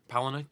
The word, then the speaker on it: Palahniuk: That's how you pronounce his name. – Palahniuk? – Palahniuk, yeah.